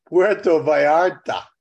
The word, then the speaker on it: Puerto Vallarta: It's Puerto Vallarta. – Okay. – The L is a Y in Spanish. So anyhow, I digress. It just cracks me up when you say it as you pronounce it as it's spelled in America.